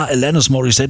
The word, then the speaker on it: Alanis Morissette: Wie spricht man die denn aus irgendwie, ne? Bis dann irgendwann hieß, ja, Alanis Morissette, die kommt aus Kanada, englische Aussprache und so.